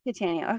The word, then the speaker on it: Cattaneo: Cattaneo.